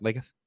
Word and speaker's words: Lakeith: Brown, Magneto, Pedro Pascal, Cyclops, Lakeith Stanfield. Did I say that right? Lakeith. Stanfield. That makes way more sense. Yep. If you break it right there, there it is.